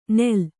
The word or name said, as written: ನೇಲ್